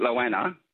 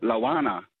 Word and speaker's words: Lowanna: The locals call it Loana, but when I'm on the phone, they all call it Loana. And it's spelled L-O-W-A-N-N-A, so it's a Loana to me.